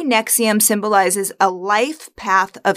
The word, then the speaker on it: NXIVM: First of all, it's pronounced NXIVM, like the heartburn medication.